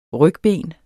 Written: rygben

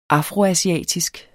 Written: afroasiatisk

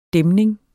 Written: dæmning

